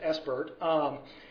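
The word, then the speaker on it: SBIRT: ESPRT, not pronounced spurt like Bert and Ernie, but it's ESPRT, is screening, brief intervention, and referral to treatment.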